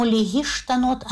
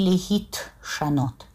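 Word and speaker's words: легиштанот (להשתנות): И вот поэтому легиштанот, а не легитшанот.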